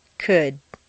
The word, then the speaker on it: could: Once you've found all the L sounds, the good news is that very often you don't even have to pronounce them. Read the following list of words after me. 1. Would, could, should.